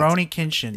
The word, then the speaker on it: Rurouni Kenshin: Rurouni Kenshin.